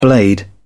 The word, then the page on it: blade: British English